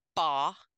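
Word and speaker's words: ba (八): 8 in Chinese is pronounced ba, and it sounds similar to fa, as in fakai, which means well off or becoming rich in a short time.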